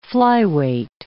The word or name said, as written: flyweight